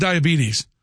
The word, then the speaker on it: diabetes: And by the way, it's not pronounced diabetes. You got to do your best Wilford Brimley. It's diabetes. Diabetes.